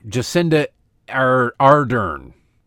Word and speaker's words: Jacinda Ardern: The prime minister there, Jacinda Ardern is how you pronounce it.